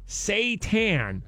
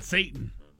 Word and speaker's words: seitan: Shanny tweets here, it's pronounced Seitan. – Seitan? – Seitan, I guess. – So not Satan.